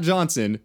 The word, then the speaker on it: Johnsohn: And one of them, his name is Alton, and one of them is named John Son. And the reason we're pronouncing it that way is because it's spelled J-O-H-N-S-O-H-N. It's not Johnson, it's John Son.